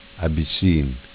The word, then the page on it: Abisin: dialetto